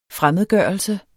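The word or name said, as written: FREMMEDGØRELSE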